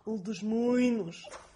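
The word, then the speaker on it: Alto dos Moinos: Oder die U-Bahn-Station, bei der, also wo ich immer aussteigen muss, wird geschrieben Altos dos Moinos und wird ausgesprochen Altos Moinos.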